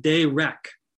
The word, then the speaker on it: derech: It's Strong's 1870, and it's pronounced derech. And the word ways, derech, so cool in the Strong's, means road, but not just a road.